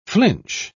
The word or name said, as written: flinch